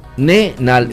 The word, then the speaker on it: nonenal: Una molécula para un olor inconfundible, el 2-nonenal, uh-huh, se pronuncia nonenal, así, uh-huh, nonenal, así la pronunciación, nonenal, nonenal.